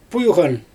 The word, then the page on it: pujGan: Xyzyl